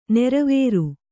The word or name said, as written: ನೀರುವರಿ